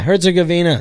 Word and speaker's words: Herzegovina: Herzegovina. – Yeah, Herzegovina.